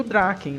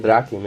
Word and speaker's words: Draken: e o Draken, né? O, o Draken, não sei como é que fala certinho o nome é dele. É Draken mesmo.